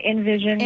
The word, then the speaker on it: InVision: On Vision, is that correct pronunciation? – Oh, we usually say InVision. – InVision, On Vision, InVision, okay, E-N, yeah.